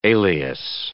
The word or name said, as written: alias